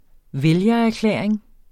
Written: vælgererklæring

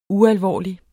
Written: ualvorlig

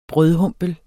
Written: brødhumpel

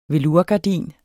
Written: velourgardin

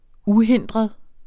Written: uhindret

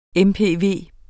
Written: mpv

